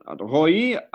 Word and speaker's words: Roei: Hello, Roei. I pronounced it correctly? – Yes, you did.